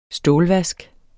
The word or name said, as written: stålvask